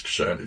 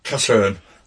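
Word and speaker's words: Casshern: It reminds me of, I don't know if you've, I'm sure I've talked about it before, a film called Kashern. I think it's Kashern. It's Korean. – Oh, Kasahern. – Kashern. – I think that's how.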